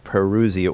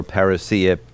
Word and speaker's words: parousia: So if you would take a few minutes to address the concept and the word parousia or parousia, different people pronounce it differently, but take a few minutes to explain that word and the significance of that word and how it relates to this discussion.